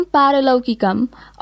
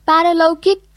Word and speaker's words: पारलौकिक: नीति श्लोकमा भनिएको छ, नगतेन द्रव्येण कर्तव्यम पारलौकिकम, अर्थात् न्यायपूर्ण रूपमा आर्जन गरेको धनबाट पारलौकिक कर्तव्य गर्नुपर्छ। – परलौकिक होइन, पारलौकिक हो है रुसा, उच्चारण गर्दा अझै ध्यान दिनुपर्छ है।